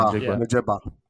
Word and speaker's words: Njigba: So yeah, but when you work out, you're going to see – Like if Smith and what's it, Njigba? How do you say that? – Njigba.